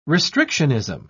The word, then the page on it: restrictionism: British English